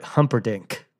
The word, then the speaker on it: Humperdinck: Uwe, can you spell Engelbert Humperdinck? – Look at that photo.